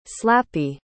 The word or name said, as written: slappy